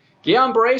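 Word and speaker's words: Guillain-Barré: Uh, the CDC is now reporting the cases of uh, Ghislaine Barr, I think that's how you say it, syndrome after being vaccinated. What is that, and, and uh, how did this show its face? – Guillain Barre syndrome, I see at my clinic, people have taken the vaccine.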